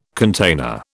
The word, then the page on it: container: British English